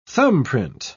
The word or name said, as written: thumbprint